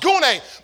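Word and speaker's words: gune (γυνή): That word woman comes from the Greek word gune. It's spelled G-Y-N-E. Y'all women I'm talking about. It's spelled G-Y-N-E. Pronounced gune.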